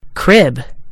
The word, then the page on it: crib: American English